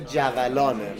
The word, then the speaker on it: جولان: تلفظ درست این کلمه جولانه، مثل جریان و قلیان و این.